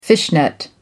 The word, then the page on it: fishnet: American English